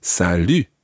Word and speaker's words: salut: It's not salut, it's salut. – Salut. – Listen carefully to the difference between bonjour and salut. Jour, lu. It's a completely different vowel sound.